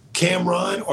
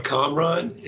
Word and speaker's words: Kamron: And I was like, my name is, there's different ways to pronounce it. So Kamron or Kamron is how you can do it.